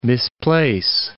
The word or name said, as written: misplace